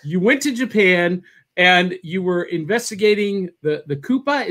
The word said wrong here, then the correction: Kappa: Is that how you pronounce it? – The Kappa. – Kappa. All right.